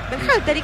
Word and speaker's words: Berhalter: Dígalo usted. – Berhalter. – ¿Y qué dije yo? – No, impecable.